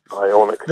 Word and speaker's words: Ioniq: In fact, the new, I can never pronounce this one, the Ioniq? – Ioniq.